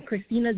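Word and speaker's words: Kristina: Yes, Christina Jones, and that's Christina spelled with a K, K-R-I-S-T-I-N-A.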